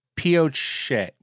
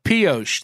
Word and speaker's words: Pioche: In our last That's My Bar, we covered Eagle Valley Resort in what I said was Posh, Nevada. And that was supposed to be pronounced Pioche. Pioche? I still don't really know how to pronounce it. Pioche. Pioche. Pioche. That's what it is.